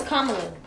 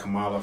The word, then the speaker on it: Kamala: It's Kamala. – That's ugly. – It's comma and love. – Yeah, like a comma. – Like C-O-M-M-A and la.